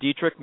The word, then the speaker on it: Dietrich: Douglas, my first name is spelled with one S, and Dietrich, my last name, is spelled just like the famous actress Marlena Dietrich.